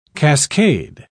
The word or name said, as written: cascade